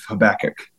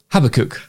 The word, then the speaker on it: Habakkuk: So in the UK, we normally say Habakkuk. – Habakkuk. Okay. All right. I'll try it. Let's see. – But you're pronouncing it probably perfectly for the US audience.